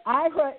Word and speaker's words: Aira: Now, Paul, we've talked around IRA, A-I-R-A. The A is silent, so I think in English we call that a diphthong, so the I is the one that takes the sound. But IRA is a game changer for blind and low vision consumers.